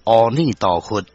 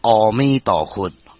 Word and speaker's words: 阿弥陀佛: 所以讲，咱即马一直伫咧念阿弥陀佛，阿弥陀佛，念一个句啊，煞变成阿弥陀佛。阿弥陀佛，因为咱台湾人是台湾腔，煞有人念阿弥陀佛。那正确的发音是阿弥陀佛，或者是阿弥陀佛，绝对不是阿弥陀佛就对。